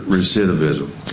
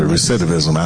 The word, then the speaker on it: recidivism: And has decided to dedicate his life to helping those recurve recidivism. Did I say that right? – Yes, you did. – I said recidivism, absolutely.